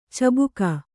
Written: ಚುಬುಕು

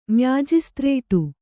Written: ಮ್ಯಾಜಿಸ್ತ್ರೇಟು

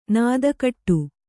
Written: ನುತಿಗೊಡು

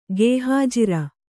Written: ಗೇಹಾಜಿರ

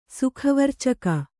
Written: ಸುಖವರ್ಚಕ

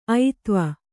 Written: ಐತ್ವ

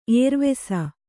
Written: ಏರ್ವೆಸ

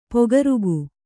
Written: ಪೊಗರುಗು

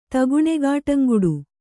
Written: ತಗುಣೆಗಾಟಂಗುಡು

